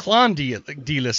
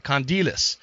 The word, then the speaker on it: Kondelis: say Clondylis, so I'm glad you did that. Kondelis.